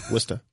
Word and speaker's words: Worcester: Worcester. – How do you say it? – They don't really pronounce the R's. – Worcester. – Well, no. It's spelled Worcester. They don't pronounce half the word.